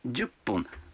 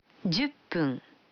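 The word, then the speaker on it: джуппун (十分): А 10 минут – джуппун. Вы заметили, что когда фун добавляется к 10, дю, то произносится пун. – Послушайте и повторите каждую фразу. 5 минут. – Гофун. – 10 минут. – Дзюппун.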